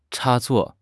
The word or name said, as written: 插座